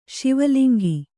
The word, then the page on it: ಶಿವಲಿಂಗಿ: kannada